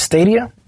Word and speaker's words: Stadia: So Google announced that they coming out with Stadia.